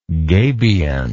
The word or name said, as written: gabionage